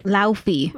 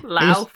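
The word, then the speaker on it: Laufey: So after was it Luffy? I don't know how to pronounce his name. The Frost Giants King. – Left Eye. – Luffy. – Luffy. – I was just corrected. Luffy.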